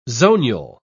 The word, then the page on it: zonule: British English